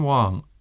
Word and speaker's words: Wang: The name is Shan Wang, which is spelled S-H-A-N and W-A-N-G.